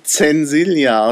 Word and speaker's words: Censilia: hat sie dann den Spitznamen Sensilia bekommen. Das ja. ging recht fix. – Also ich würde es dann Zensilia aussprechen, damit man das Zensur darin auch hört.